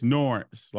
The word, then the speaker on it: Norense: But it's Norense, like Lawrence, but with an N.